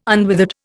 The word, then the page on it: unwithered: Indian